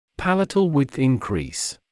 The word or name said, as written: palatal width increase